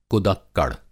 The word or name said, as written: कुदक्कड़